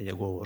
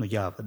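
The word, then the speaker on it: Яхве: Потому что вот Иегова, Явы, правильнее сказать, это Иегова уже как-то так не совсем тут, у нас наши еговиты, немножко как-то так, вот, Явы, вот такое, ну, Явы, да, более правильное произношение.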